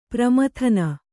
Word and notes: ಪರಮೋದನ: paramōdana

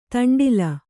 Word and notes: ದಂಡ್ಲ: daṇḍla

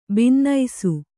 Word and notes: ಭಿನ್ನಿಸು: bhinnisu

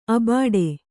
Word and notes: ಅಬಾಡೆ: Kannada

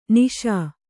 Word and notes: ನಿಷಾ: kannada

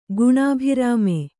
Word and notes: ಗುಣಾಭಿರಾಮೆ: guṇābhirāme; kannada

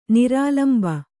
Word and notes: ನಿರಾಲಂಬ: nirālamba

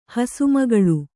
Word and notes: ಹಸುಮಗಳು: kannada

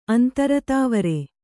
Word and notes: ಅಂತರ್ದ್ವಾರ: kannada

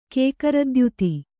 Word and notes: ಕೇಕರದ್ಯುತಿ: Kannada

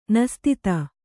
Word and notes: ನಸ್ತಿತ: nastita

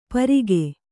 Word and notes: ಪರಿಖೆ: kannada

